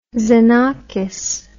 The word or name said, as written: xenakis